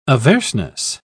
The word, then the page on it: averseness: British English